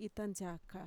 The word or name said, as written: itantxaka